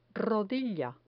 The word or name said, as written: rodilla